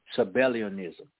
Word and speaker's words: Sabellianism: I hope I'm pronouncing it right. It is S-A-B-E-L-L-I-A-N-I-S-M, Sabellianism, okay?